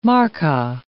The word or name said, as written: markka